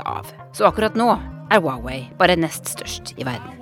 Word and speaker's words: Huawei: Hawaii? Hawaii? – Huawei. Huawei. Hawaii. Ok. Denne mobiltelefonen vi ofte kaller Huawei, skal egentlig uttales Huawei.